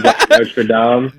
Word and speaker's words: Notre Dame: English people are going to hate me pronouncing it that way, but I am going to use the American pronunciation. But instead of Notre Dame.